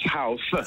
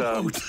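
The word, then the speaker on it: Howth: Which has an American, I'd pronounce house. – House, yeah, that's called Hout.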